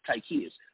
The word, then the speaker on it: Tychius: You put Tychius, and that's how you say my name, it's Tychius.